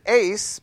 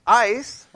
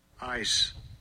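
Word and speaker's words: ice (εἰς): Now, for some reason, some people pronounce it ace, but it's ice, right? – Strong's G1519, ice.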